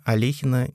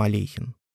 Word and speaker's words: Алехин: По воспоминаниям некоторых современников, он очень злился, если кто-то по незнанию или нарочито произносил его фамилию с буквой «ё». С этим Алехин боролся как мог. И когда маститые учителя Поливановской гимназии ёкали, он их поправлял, не стесняясь своего юного возраста.